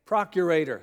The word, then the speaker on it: procurator: She told me it's like alligator. Procurator. Thank you. See, I've gone procurator, procurator, procurator, alligator. There's a song in there somewhere. I'll just say the Roman ruler. The procurator of Judea, Pontius Pilate, rightly concluded that Jerusalem needed a better water supply.